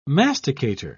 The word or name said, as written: masticator